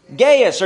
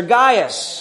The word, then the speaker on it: Gaius: Gaius or Gaius. There's two different ways to pronounce that.